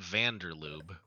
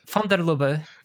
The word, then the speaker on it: van der Lubbe: Not van der Lubbe, but... – I would have to see how it's spelled. – L-U-B-B-E. – L-U-B-B-E. Lubbe. – Stupid. – Yeah. von der Lubbe.